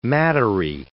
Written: mattery

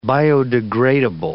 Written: biodegradable

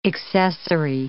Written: accessory

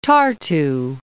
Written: Tartu

